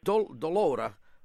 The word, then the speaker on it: Dolora: Delora? – Delora. – How do you spell that? – D-O-L-O-R-A. – Delora.